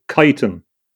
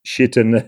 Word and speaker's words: chitin: Another subject that they have been very interested in from that time is chitin, or chitin, or C-H-I-T-I-N is pronounced in various ways. Some people say chitin, it's chitin, I think, is the best pronunciation.